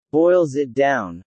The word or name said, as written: boils it down